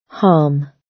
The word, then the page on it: harm: British English